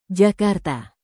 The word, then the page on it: jakarta: indonesian